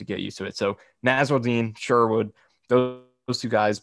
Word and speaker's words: Nasirildeen: Pronounce his name for me. – Nasirildeen. Florida State safety.